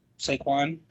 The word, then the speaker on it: Saquon: Saquon.